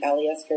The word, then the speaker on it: Alyeska: I see that you visited the Alyeska Resort.